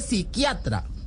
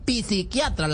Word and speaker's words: psiquiatra: Y le aclaro de una vez que se dice psiquiatra, no psiquiatra, la P no se pronuncia.